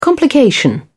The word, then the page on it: complication: British English